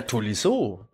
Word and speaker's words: Tolisso: wie der neue Franzose ausgesprochen wird? Ja, Tolisso, Tolisso, ne?